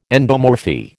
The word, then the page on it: endomorphy: American English